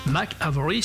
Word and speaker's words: Mick Avory: Mac Avory si je prononce ça à l'anglais, ce dernier pète les plombs et envoie donc Davis à l'hôpital.